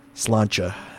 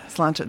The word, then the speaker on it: Sláinte: Sláinte. – Sláinte. That is not how I was saying it, so I'm glad you said it to me so I know how to say it.